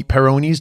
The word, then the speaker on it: Peyronie's: uh, I think what she was talking about was extreme uh, par- paranoid's disease, Peyronie Peyronie's disease.